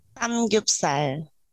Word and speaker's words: Samgyupsal: Samgyupsal.